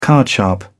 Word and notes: card sharp: British English

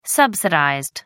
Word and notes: subsidized: American English

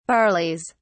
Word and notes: barleys: British English

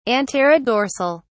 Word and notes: anterodorsal: British English

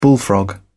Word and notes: bullfrog: British English

